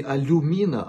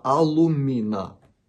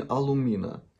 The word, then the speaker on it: алумина: Не алюмина, а алумина. Пишется так по-английски. Если вы обращаетесь в аптеку где-нибудь на территории России, ну, скорее всего, вам предложат именно алюмина. Это одно и то же. Но правильное произношение алумина.